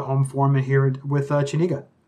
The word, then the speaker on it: Chenega: You could also look up Chenega MIOS, and Chenega is pronounced like your chin, Chenega, and then MIOS.